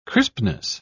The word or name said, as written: crispness